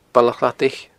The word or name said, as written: Baile a' Chladaich